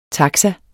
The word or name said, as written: taxa